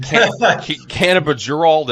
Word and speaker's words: Cannabidiol: Cannabidiol, did I say it right? – You nailed it.